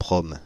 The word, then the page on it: PROM: France (Île-de-France); /pʁɔm/